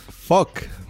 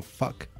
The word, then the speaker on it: fuck: Se pronuncia fuck. O sea, es como entre A y O, ¿no? Es como fuck. Entonces, fuck you. Yo a ustedes sí les veo muy marcado el A, ¿no?